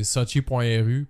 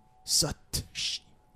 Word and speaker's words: Sochi: Chris qui a dit que, gars, la prononciation de Sochi, ça va être pour, mettons, des francophones, ça va se dire avec un T.